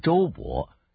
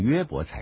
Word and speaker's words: 约伯: 现在我们要来看约伯记，英文把约伯翻译成周伯，但是希伯来文的发音是约而不是周，所以应该念成约伯才对。